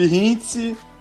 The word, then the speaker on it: Hintz: É isso aí, eu sou o Felipe Hintz, é arroba Felipe mesmo, H-I-N-T-Z.